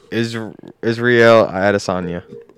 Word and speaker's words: Israel Adesanya: Israel Adesanya.